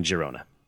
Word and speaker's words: Girona: I looked it up. Girona is a Catalan word. So it's Je. So there we go. Apologies for ever calling it anything other than Girona.